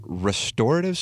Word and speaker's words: restorative: Four hours and 30 minutes of, do you call it restorative sleep? – I never say that, no. – It's restorative. – That's what I was wondering. Is it restorative or restorative? – Put the emphasis on the first syllable.